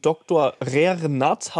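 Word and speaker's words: Dr. rer. nat.: Er, äh, Herr Doktor Faber, äh, hat auch einen Doktor Rernat.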